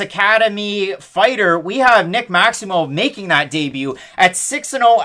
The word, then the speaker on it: Maximov: Carl Roberson, Nick Maximov, almost like mauve, like the color. That's how he pronounced it on Contender Series. That's how the commentators say it.